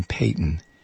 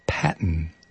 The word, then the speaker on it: Patton: 10 before the hour, the writer from the Orlando Independent Examiner, whose work I quote on this program frequently, I've been pronouncing his name Peyton. It's not. It's Patton, as in general.